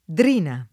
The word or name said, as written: Drina